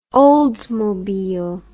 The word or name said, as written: Oldsmobile